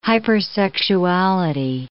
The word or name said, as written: hypersexuality